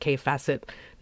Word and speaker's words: Kaffe Fassett: If that's apparently how you pronounce his name, if you look at it and you think it's Kaif Facet, you are not alone.